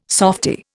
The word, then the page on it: softy: Indian